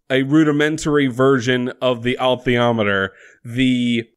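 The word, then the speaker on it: alethiometer: And it's interesting, like, basically, Professor Maloney has built a high-tech, please God, atheometer. Did I get it? – I mean, there's an L in there. – God damn. – I say altheometer, but to be fair, perhaps it's a silent L.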